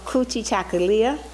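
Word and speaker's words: Kujichagulia: The second one is called Kuji Chakalia. I'll help you pronounce that. Kuji Chaka Lia.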